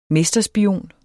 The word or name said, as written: mesterspion